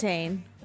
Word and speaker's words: Lamontagne: Lamontagne.